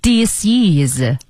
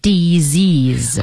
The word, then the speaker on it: disease: 但注意哦，它的读音不是 disease，它的读音叫做 disease 啊，就是那个 s 发成 z 的音和后面的元音连在一起了。